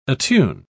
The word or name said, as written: attune